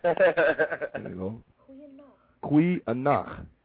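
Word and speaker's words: goeie nag: That's N-A-G. Kui anach. Good night. – Kui anach. – These spellings are G-O-E-I-E space N-A-G. Kui. G is spelled, is pronounced Q. So when you're pronouncing these, you have to pronounce it with the G with the Q. So it's kui nach.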